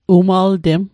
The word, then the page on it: umaltem: Mi'gmaq